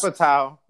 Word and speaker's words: Judd Apatow: I am a huge fan of Jew Apatow, if I'm saying his name right. – Not even close. – Is it Apatow? – No, he'll never listen to us. – Judd Apatow.